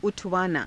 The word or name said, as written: u_thavaanaa